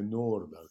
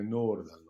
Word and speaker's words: Nordahl: E se ti dice Nils Lidholm che lui, uno come Nordahl, come lo chiamava lui, con la pronuncia giusta, come Nordahl, non si è mai più visto, fidati, probabilmente non si è mai più visto davvero.